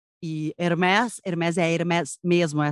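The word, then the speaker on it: Hermès: mas Hermes com S, então não me corrijam, não tentem afrancesar.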